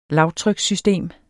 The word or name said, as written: lavtrykssystem